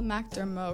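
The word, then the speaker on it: MacDermot: followed by Coffee Cold by Galt McDermott. Is that how I say it? – It's like Gal McDermott, because I think he's French.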